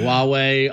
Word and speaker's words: Huawei: and I believe I said it correctly. Huawei Honor 8 Pro. – You got it.